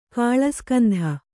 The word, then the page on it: ಕಾಳಸ್ಕಂಧ: kāḷaskandha